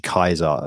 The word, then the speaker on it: Caesar: So if you're going to pronounce it like Caesar, like we always say Caesar, but it's actually Kaiser, isn't it? Is how it would be pronounced in Latin. So if you see P-E-N, you're like, well, it's probably pen rather than like any softer way of putting it.